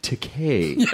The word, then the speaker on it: Takei: It's pronounced Takei. It rhymes with gay. As in, it's okay to be Takei.